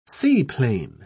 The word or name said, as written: seaplane